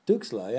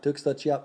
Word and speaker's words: Tuxtla: Tuxtla, yeah. Tuxtla, Chiapas.